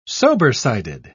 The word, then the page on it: sobersided: British English